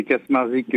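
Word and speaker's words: Kaczmarczyk: Donc c'est Kasmarzik euh, Joseph.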